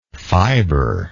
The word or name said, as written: fiberless